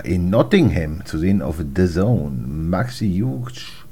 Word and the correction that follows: Hughes: Huge, falls er so heißt. – Hughes, Hughes. Hughes. Glaube ich, spricht sich das aus. Ja, ja, ich weiß, wie Mark Hughes, der, der Fußballer früher, ah, okay.